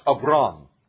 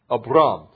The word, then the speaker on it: Abram: Now the Lord had said to Abram, get out, it's actually pronounced Abram, Abram.